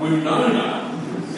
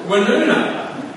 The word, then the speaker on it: Woonona: W-O-L-L-O-N-G-O-N-G is pronounced not Wollongong, but Wollongong. And while we're at it, how come Woonona is pronounced Winona?